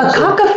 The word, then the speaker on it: Akaka: Akaka Falls. – Akaka Falls.